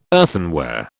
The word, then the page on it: earthenware: British English